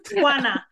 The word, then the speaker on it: Juana: Juana.